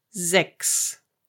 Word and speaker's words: sechs: If CH is followed by S, then you pronounce it K. So the number six is sechs.